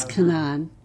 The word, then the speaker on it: Canaan: And they use the Palestinian pronunciation, which is Canaan.